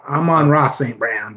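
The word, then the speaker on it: Amon-Ra St. Brown: Armon St. Brown. Did I say it right that time? – Yes, you forgot the Ra. Amon Ra St. Brown. You're good otherwise.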